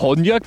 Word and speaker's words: Honja: Honja bekomme ich hin.